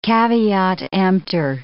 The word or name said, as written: caveat emptor